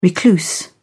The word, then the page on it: recluse: British English